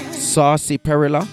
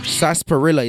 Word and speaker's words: Sarsaparilla: Saucy Perilla. – She loves to smoke. – You hear it? Sarsaparilla is what he's really saying, people. But some Jamaican pronounce it Saucy Perilla.